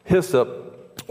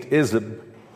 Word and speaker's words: hyssop: Hyssop, or as it may have been pronounced in the Middle East, izzib, is a hardy flowering bush-like plant that possesses an aromatic kind of minty fresh scent and a warm, pungent flavor.